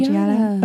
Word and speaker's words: Giada: So I think is it Giada? How does she say her name? – Giada. Okay.